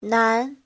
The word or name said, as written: nan2